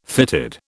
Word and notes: fitted: British English

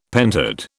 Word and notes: pentad: British English

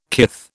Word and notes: kith: British English

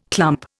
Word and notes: clump: Indian

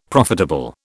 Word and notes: profitable: British English

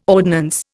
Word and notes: ordnance: Indian

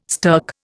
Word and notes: stirk: Indian

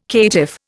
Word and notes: caitiff: Indian

